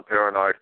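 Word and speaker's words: Peronard: You can look me up on, I always tell people, you know, you can look me up on Facebook at Sean Perrenard, and you could check it out on Nancy's page here.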